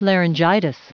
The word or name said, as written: laryngitis